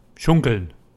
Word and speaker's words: Schunkeln: Schunkeln. – Schunkeln. – Schunkeln. – Spell it. – S-C-H-U-N-K-E-L-N.